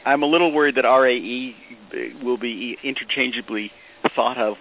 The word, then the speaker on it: RAE: And then, as I said, what we'll do is when we use ray, we'll just define it then with... – You see, you just pronounced it ray, not R-A-E. – R-A-E, I'm sorry. R-A-E.